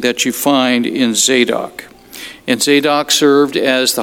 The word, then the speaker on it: Zadok: The man's name is Zadok, Z-E-Z-A-D-O-C, Zadok. And I sometimes wondered how to say it because I don't speak Hebrew, but I looked it up and it is Zadok.